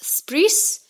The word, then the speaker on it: spritz: Usually coming from the Venice or Veneto region, pronounced spris. And it's derived from the German word spritzen, which means to spray or to splash.